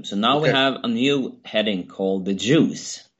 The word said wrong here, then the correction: Druze: Oh no! The Druze. you're pronouncing it as Jews. That's what I heard. The Druze. Yeah, Druze. D-R-U-Z-E. Yeah, and I could see it could be pronounced Jews. right. But it's the Druze.